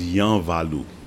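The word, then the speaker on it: Yanvalou: You talked about during the concert the Balu rhythm, I don't know if I'm saying it right, featured in the piece titled Moonlight in Haiti. – Okay, that was Yan Valu.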